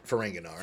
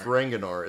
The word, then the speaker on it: Ferenginar: When we actually get, is this our first look at Ferengiar? Is that how you say it? – Ferenginar. – Ferenginar.